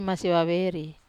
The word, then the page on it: masivaveri: Wamesa